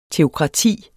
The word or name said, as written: teokrati